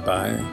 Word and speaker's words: boy: In County Down, where I live, you'd be more likely to hear the word boy, though it'll be pronounced by, as in, how's it going there, by?